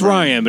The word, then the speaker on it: Rian: It's Ryan, but it's spelled Rian.